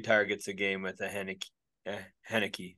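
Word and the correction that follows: Heinicke: Am I saying that right? – Heineke. Kind of like the beer.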